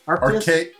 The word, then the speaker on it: Arceus: Arceus. Arca- Ar- Arceus. Arceus. – Yes. Yeah.